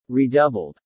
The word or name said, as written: redoubled